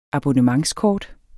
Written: abonnementskort

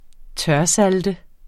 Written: tørsalte

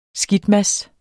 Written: skidtmads

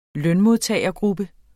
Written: lønmodtagergruppe

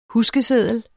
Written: Huskeseddel

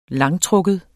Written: langtrukken

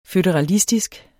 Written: føderalistisk

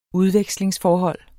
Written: UDVEKSLINGSFORHOLD

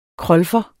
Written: krolfer

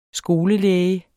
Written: skolelæge

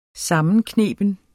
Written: sammenkneben

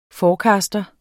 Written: forecaster

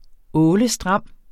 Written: ålestram

